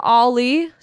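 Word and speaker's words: Ali: We got Ollie, spelled like Allie.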